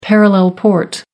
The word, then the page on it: parallel port: American English